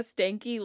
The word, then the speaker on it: Stanky: Sorry, his last name is Stanky? – It's like a stanky leg? – It's spelled differently, but yeah. Stanky.